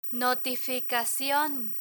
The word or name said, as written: notificacion